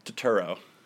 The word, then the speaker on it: Turturro: Turturro. You said it like my neighbor Turturro. Which is a very funny concept. It's just my neighbor Turturro. It's just not Turturro. So yeah, that's one. – Turturro.